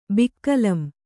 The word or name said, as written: ಬಿಕ್ಕಲಂ